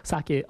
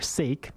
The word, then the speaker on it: sake: Sake, obviously, as you may know, is also known as rice wine. Most people I talk to, sometimes they pronounce it as sake or sake, but no, it's sake.